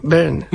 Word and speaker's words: Byrne: kako se izgovara zapravo David Byrne? Byrne. – Ja mislim Byrne, da David Byrne, da.